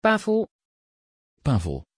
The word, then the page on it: pavel: Dutch